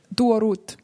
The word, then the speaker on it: Duo Ruth: Duo Ruth.